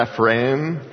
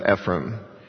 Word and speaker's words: Ephraim: And Ephraim, when he uses the term Ephraim or Ephraim, I think is a better pronunciation in Hebrew, that's the northern 10 tribes of Israel where their capital is Samaria.